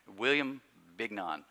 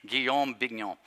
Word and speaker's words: Guillaume Bignon: He's a modern French theologian named Guillaume Bignon. Have I told that story? Because I always butcher that name. William Bignon. I don't know. Guillaume Bignon. So that's the way I'm going to say it.